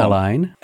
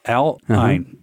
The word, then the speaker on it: Al Ain: United Arab Emirates is where Al Ain, saying it wrong. – Al Ain. – Al Ain. Okay. – It's a different letter in Arabic.